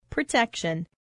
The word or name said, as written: protection